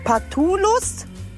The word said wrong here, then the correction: Phallus: Ist das richtig ausgesprochen? – Phallus.